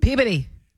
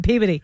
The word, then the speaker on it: Peabody: Peabody. – Peabody? – Yeah. I'm in Peabody.